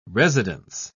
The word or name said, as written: residence